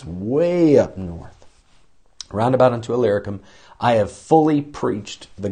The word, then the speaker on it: Illyricum: Alicrium. I've always mispronounced it. And then one day I slowed down and looked at that word and thought, that is not Alicrium, it's Illyricum.